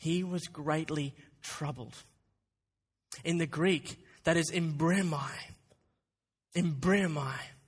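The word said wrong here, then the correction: embrimaomai: Okay, you're pronouncing it wrong. And to help us with the pronunciation, we'll listen to the Logos software, give us the right pronunciation of this. Here we go. – Embremaomai. – Embremaomai. That is how you pronounce it? – Embremaomai.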